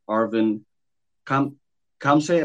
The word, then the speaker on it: Arvin Khamseh: This is @LunumInc, and of course, today we are talking about the future of NFTs with Arvin Khamseh.